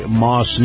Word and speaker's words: Maus: And by the way, this portion of the program, my conversation with retired Lieutenant Colonel Kevin Wright, brought to you by Moss Nissan. Moss is spelled M-A-U-S.